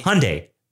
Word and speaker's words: Hyundai: Like Hyundai, like Sunday. – Hyundai. Oh, yeah. I always say Hyundai. – Yeah, that they had a commercial that taught you how to say it here in the States.